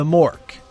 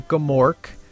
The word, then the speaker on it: Gmork: It's spelled G-M-O-R-K. So like you, like Gnome, I would think that the G is silent, right? So I would think that it would be the Mork, you know, M-O-R-K. You know, the G is silent. But in the Bastion in the movie says the Gamork.